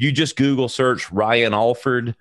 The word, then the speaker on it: Alford: It's A-L-F-O-R-D for people at home, but it's pronounced Alford.